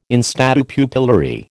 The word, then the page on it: in statu pupillari: American English